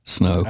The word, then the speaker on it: sneaux: And you know, they all spell it S-N-E-A-U-X, snow.